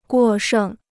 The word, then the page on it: 过剩: guò shèng